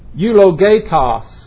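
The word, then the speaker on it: eulogetos (εὐλογητός): Be sure to underline that first word if it says blessed in your Bible, because that Greek word is eulogetos, E-U-L-O-G-E-T-O-S. That's a long E, an eta instead of epsilon.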